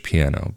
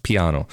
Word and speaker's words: piano: But moving on, there's another false friend that you sometimes find between Italian and English, and that's the word that looks like in English, piano, but in Italian it's pronounced piano.